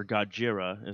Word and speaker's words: Gojira: Or Gojira is how they pronounced it.